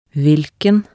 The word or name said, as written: hvilken